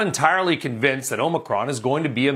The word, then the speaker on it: Omicron: And by the way, that is how it's pronounced. It is Omicron. It's not Omicron or anything else.